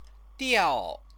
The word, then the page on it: diao: chinese